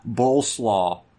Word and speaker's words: Boleslaw: He was welcomed in Hungary by King Boleslaw. It reminds me of coleslaw. It's spelled just like coleslaw, but it's Boleslaw.